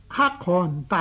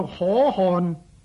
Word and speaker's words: 可汗: 在木兰词里面有啊，昨夜见军帖，克汗大点兵，这两句有些人呢将克汗两个字呢直读，读作可汗，这样呢是不对的。比较正确的读音呢是将个可字呢读作克，将个汗字呢读作汗。